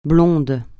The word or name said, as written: blonde